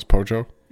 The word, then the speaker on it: Pojo: everybody remembers that the it's Pojo, right? I'm saying it right now is Pojo. – Yes. – Okay. Yep, good.